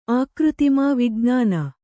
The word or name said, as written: ಆಕೃತಿಮಾ ವಿಜ್ಞಾನ